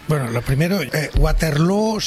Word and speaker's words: Waterloo: Pues no lo es, desde luego, pero ABBA ha hecho mucho daño en la pronunciación de Waterloo, que se pronuncia igual que Feijóo.